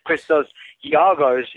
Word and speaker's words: Christos Giagos: Yeah, that was a very good pronunciation of Christos Giagos, even though most Americans would pronounce it Giagos.